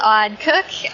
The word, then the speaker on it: Aud Koch: Hello, my name is Aud Koch.